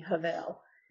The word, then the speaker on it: Hevel: Actually, if you say it right in Hebrew, it would be Havel. No, not this isn't a het. So yeah, it's a hay. So H-E-V-E-L.